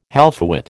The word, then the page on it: halfwit: American English